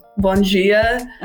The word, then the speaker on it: bom dia: For example, Bonjia. – uh-huh. – when I was reading it, trying to learn, to me it's bom dia.